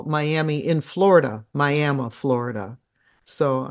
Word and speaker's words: Miami: So that's the Pitcher Mining District in, it's spelled Miami, M-I-A-M-I, but it was named for a man's wife, so it's pronounced Miami.